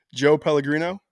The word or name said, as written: Joe Pellegrino